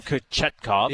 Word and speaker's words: Kochetkov: I'm just trying to look up because Kochkov, and I always pronounce it wrong. – Kochetkov. Yep.